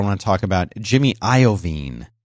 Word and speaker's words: Iovine: Did you know that's how you're supposed to say his name? – I did not know that. – Jimmy Iovine. – Iovine. – Like Jeffrey Veen. Iovine. – Not like iodine. – Not like iodine, but just with a V, Iovine, which is how I thought you said it.